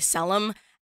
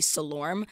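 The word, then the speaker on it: Selorm: And so my name is pronounced Selim. – Mm-hmm. – But I think that when I was younger, I had my family call me Selim. And then like it just started to happen where like people outside of my family in school would call me Salorm. – Mm-hmm. – Just because they're looking at the way my name is spelled.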